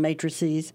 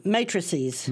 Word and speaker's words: matrices: Appendices and matrices, although appendixes is preferred. – So that Keanu Reeves trilogy is the matrixy. How do you say the plural again? – Matrices.